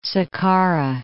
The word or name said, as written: Sakkara